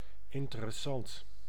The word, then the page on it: interessant: [in.te.ɾǝ.sɑnt]